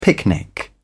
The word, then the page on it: picnic: British English